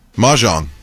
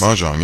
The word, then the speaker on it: Mahjong: Mahjong? – Mahjong. – Is that what it is? – Mahjong, yeah.